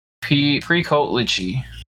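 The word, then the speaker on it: Pricolici: So there's another type of werewolf called the Precoatlet. Precoatlichy. That's what Wikipedia says it's pronounced.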